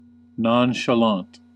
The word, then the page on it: nonchalant: American English